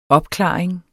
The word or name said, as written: opklaring